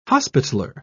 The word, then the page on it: hospitaler: British English